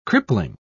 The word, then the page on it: crippling: British English